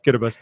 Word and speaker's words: Kiribati: And I'll never forget when, Devin, you were on the show earlier this spring and we talked about the proper pronunciation of Kitabas.